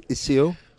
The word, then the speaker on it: Iseo: Riva del Garda to Iseo. Is that a correct pronunciation? Iseo. I said it wrong earlier then.